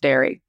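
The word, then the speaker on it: Därë: Literally the first thing he wrote down was D-A-R-E, umlaut over the E. He said, it's pronounced dairy.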